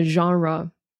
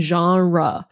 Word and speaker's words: genre: 还是他的设定，我觉得他的设定非常的有趣，就是在一个虚幻的世界里面，这应该算是科幻吧，如果要分类的话，因为我们会说他的 genre，诶，我有这个 YouTube 影片告诉大家怎么样发音 genre，它听起来像是一个法语的单字对吗？但它也确实是，所以那个 G 你不能把它念成 J， right？它是 Z，是有震动的，像我们说 Z 的那个 Z 一样。